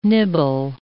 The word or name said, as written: nibble